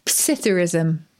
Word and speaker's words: psithurism: Is the P pronounced? Should it just be Psithurism? – Well, you can pronounce it either way. That's a good point. Dictionary will give both.